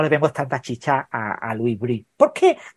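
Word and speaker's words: Louis Brus: Louis Brie se escribe Bruce,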